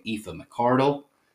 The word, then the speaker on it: Aoife McArdle: Directing her second straight episode, so I had to look up how to pronounce her name, Aoife McArdle.